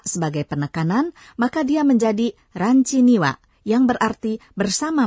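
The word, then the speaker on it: Ranchi (ランチ): Ranchi adalah pengucapan bahasa Jepang untuk kata lunch atau makan siang.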